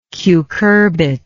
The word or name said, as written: cucurbit